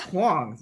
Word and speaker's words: Hoàng: It's Hoàng.